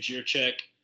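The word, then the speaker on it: Jędrzejczyk: And then Zhang Weilian, Joanna, man, how do you say her last name? Jiříček. – Yeah.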